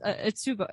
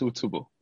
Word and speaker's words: Utsubo: the website that I'm talking about is Utsubo. Am I saying that right? – Yeah, you're right. It's all tubo.